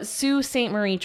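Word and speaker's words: Sault Ste. Marie: And it's written like salt. And she even comments like, don't say that. – Don't say it like the tourists do. Yeah. So Sioux St. Marie tribe.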